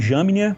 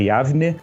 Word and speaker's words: Yavne: Alguns sites colocam como Jamnia, como Jamnia, mas a, a pronúncia é Yavne,